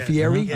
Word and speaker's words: Fieri: Is that the way you pronounce his name? Is it Fieri? – Uh-huh. Yes. Uh-huh.